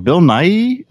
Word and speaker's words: Nighy: I always say this gentleman's name wrong. Bill Nighy. Is that his? He was. – Yeah, spot on. – I'm sorry. – Spot on. You got that exactly right.